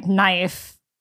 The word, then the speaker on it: knife: It's like how we can't explain why knife starts with the letter K. – Exactly. – And we don't pronounce it.